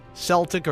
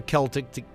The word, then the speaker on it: Celtic: All right, as well, it's Celtic or Celtic, depending on which pronunciation you go with. Both are correct.